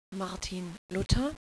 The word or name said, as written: Luther